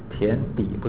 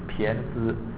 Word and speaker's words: 胼胝: 胼不是胼，好像念把它念成胼底，不是胼底啊，胼胝，胼胝，